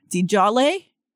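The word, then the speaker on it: DesJarlais: I don't know how to say his last name. – Dejaris. – Yeah. Dejarlace.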